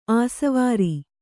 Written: ಆಸವಾರಿ

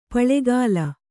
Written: ಪುಲ್ಗಲಿ

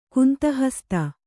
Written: ಗಂಧಹಸ್ತಿ

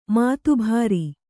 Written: ಮಾತಬರ

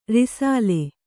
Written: ರಸಾಲ

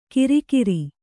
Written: ಗಿರಿಗಿರಿ

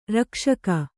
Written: ರಕ್ಷಕ

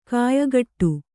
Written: ಖಯಖೋಡಿ

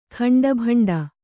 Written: ಖಂಡಭಂಡ

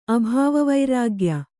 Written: ಅಭಾವವೈರಾಗ್ಯ

